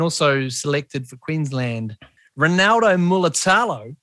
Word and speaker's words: Mulitalo: So his surname is Mulitalo. – Mulitalo. – Mulitalo. – Muli sounds like woolly, basically. – Mulitalo. Mulitalo. Ronaldo Mulitalo.